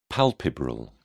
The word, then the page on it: palpebral: British English